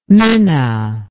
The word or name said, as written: minna